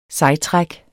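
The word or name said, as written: SEJTRÆK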